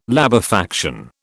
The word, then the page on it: labefaction: British English